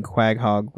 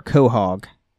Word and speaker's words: Quahog: Uh, Daddy Bat Night said Family Guy takes place in Quaghog, Rhode Island, pronounced co- or Quahog from our last episode on the MACUSA.